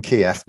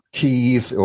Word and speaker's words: Kyiv: Because Kiev, we always used to eat a chicken Kiev, but not anymore, because apparently Kiev is how the Russians pronounce it, and Kyiv or Kyiv is how the Ukrainians pronounce it.